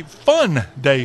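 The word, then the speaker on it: Phun Day: Also going on this weekend in the Old Dominion State, you have a Fun Day Festival. That's spelled P-H-U-N. Fun Day Festival, that is in Virginia Beach, and that's taking place this weekend.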